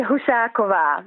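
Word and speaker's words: Husáková: Now, I know that a lot of Australians call it Husakova, but how do you say it? – You say it Husakova, which is the Czech pronunciation.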